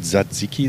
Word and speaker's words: Tzatziki: Beziehungsweise Tzatziki habe ich gelernt. – Du hast es gelernt. – So wird es ja ausgesprochen. Mhm. Tzatziki sagen wir Griechen.